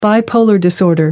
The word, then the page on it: bipolar disorder: American English